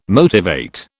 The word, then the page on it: motivate: British English